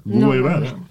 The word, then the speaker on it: Guevara: O Guevara, Guevara. – Guevara. se pronuncia, señor.